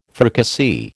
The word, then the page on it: fricassee: American English